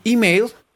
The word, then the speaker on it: email: Cuando uno escribe un email, email se escribe con E, pero se pronuncia con I. Email, acuérdense por el email.